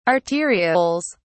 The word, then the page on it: arterioles: British English